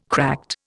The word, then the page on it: cracked: Indian